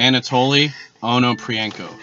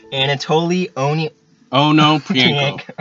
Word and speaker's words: Anatoly Onoprienko: Anatoly Onoprienko. – Anatoly Onoprienko.